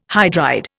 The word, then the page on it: hydride: Indian